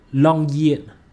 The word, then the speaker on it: Longyearbyen: Nếu mà du ngoạn xuống thị trấn Longyearbyen, thị trấn này đọc là Longyearbyen, Longyearbyen, cái này là tiếng Na Uy các bạn, Longyearbyen, Longyearbyen chứ không phải Longyearbyen ha, Longyearbyen.